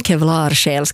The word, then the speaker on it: Kevlarsjäl: Jag säger alltid Kevla, ja. Men svenskarna ska alltid göra det svårt. – Jo, men det är för att alla känner Kent-låten och där man har det uttalet på det. Men Kevlarsjäl ska det tydligen rent språkligt heta.